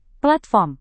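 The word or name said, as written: platinum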